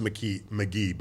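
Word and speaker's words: MK.Gee: He's a guy from, and actually I think it's pronounced McGee, but it's M, the letter M, K dot G E E, MKG.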